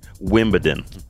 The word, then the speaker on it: Wimbledon: Wimbledon. Yeah, that's how you pronounce it.